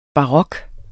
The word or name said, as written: barok